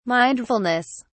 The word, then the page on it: mindfulness: British English